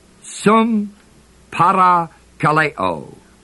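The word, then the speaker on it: sumparakaleō: Comforted together is the word sum parakaleo, spelled sumparakaleo, long O.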